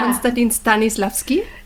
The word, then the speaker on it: Konstantin Stanislavski: Konstantin Stanislavski.